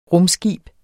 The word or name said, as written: rumskib